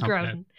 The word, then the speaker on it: Grodin: That is an insane way to pronounce Charles Grodin's name, and you have to keep that in the cup. It's Charles Grodin.